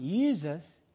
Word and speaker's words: Jesus: It's a little hard to pronounce, but it's spelled I-E-S-O-U-S in the Old English. And then that became Jesus, I-E-S-U-S. Pronounced, I mean, it looks like Jesus, but it is pronounced with a Y, Jesus. But it begins with the letter J, but pronounced with the Y sound.